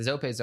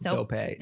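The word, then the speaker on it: Zoppé: I just don't know how to pronounce it. – Zope. Zope. Z-O-P-P. – Yes, with a little accent aigu.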